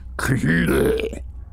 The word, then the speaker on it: Cthulhu: The actual sound, as nearly as any human organs could imitate it or human letters record it, may be taken to something like Cthulhu. With the first syllable pronounced gutturally and very thickly, the U is like that in full, and the first syllable is not unlike Kalul in the sound, hence the H represents the guttural thickness.